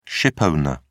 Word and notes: shipowner: British English

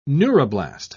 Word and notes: neuroblast: British English

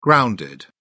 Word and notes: grounded: British English

